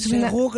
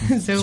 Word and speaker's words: Serreau: C-O-L-I-N-E, Coline, y luego S-E-R-R-E-A-U. – Colin Serreux. – Venga. pues Segú, que se dice así. – Segú. – Segú.